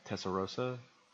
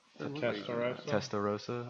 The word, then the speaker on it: Testarossa: I always pronounce it wrong. Tessarossa. – Testarossa.